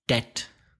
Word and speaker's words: debt: Moving on to words with a silent B. Subir, ascender, escalar. – Climb. – Deuda. – Debt. That's D-E-B-T.